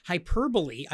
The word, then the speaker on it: hyperbole: And it's actually pronounced hyperbole. I thought it was hyperbole.